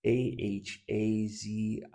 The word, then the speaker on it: Ahaziah: But Ahaz, I never can pronounce his name right, but Ahaziah.